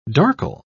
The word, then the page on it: darkle: British English